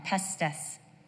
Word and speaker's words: pistis: The Greek word for faith is the word pistis. That's how it's pronounced, pistis.